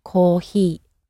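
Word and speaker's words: kohee (コーヒー): So the Japanese sound is somewhere in between an H and an F. So the word for coffee is kohee.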